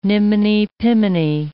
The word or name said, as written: niminy-piminy